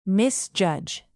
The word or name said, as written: misjudge